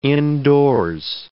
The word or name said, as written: indoors